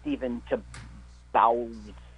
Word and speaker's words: Stephen Tobolowsky: Well, you know, he also, Stephen Tobolowsky. – That's how you pronounce it.